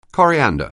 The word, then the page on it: coriander: anglais